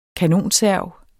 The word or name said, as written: kanonserv